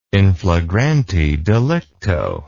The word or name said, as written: in flagrante delicto